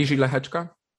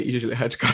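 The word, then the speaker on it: Jiří Lehečka: So how do you say his name? Pardon my lack of knowledge, like so pronounced slowly so the listeners can also pick it up. – Mm-hmm. Iži Lehečka. – Okay. All right. – I believe so because, you know, I don't know Czech either, but it's all the people just nod when I say Iži Lehečka. So I'm assuming that that is how it's pronounced.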